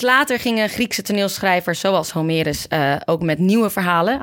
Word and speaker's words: Homerus: En pas later gingen grote Griekse toneelschrijvers, zoals Homerus, zeg ik het goed, Geiten? – Ja, volgens mij wel. Homerus.